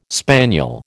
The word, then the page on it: spaniel: American English